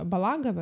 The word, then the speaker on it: Балагов: Есть там пример режиссера Балагова, угу. Да, который... – Кентимир, да. – Да, Балагов, правильно говорю? – Да-да-да.